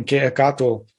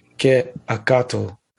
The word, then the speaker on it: Quetzalcoatl: Yeah, if I'm saying that correctly, it's spelled C-E, and then the second part is A-C-A-T-L, Quetzalcoatl.